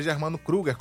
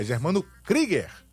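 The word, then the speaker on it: Germano Krüger: Então, não é Germano Kruger, como Fred Kruger, que ele brincou aí, o João Bosco, né? É Germano Krieger, a pronúncia do estádio do Operário.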